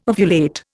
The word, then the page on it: ovulate: Indian